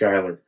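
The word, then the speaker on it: Schuyler: And I'm going down the list, and I come across this name, and I just pronounced it as I read it. And I'm like, Shuler. And I felt like I was in Ferris Bueller's Day Off, because I just went, Shuler, Shuler, Shuler. And then finally, the young man speaks up and goes, it's pronounced Skyler.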